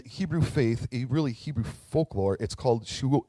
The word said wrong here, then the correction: Sheol: sheol no i'm saying it wrong um, shoal Sheol. Sheol, that's what it is, right?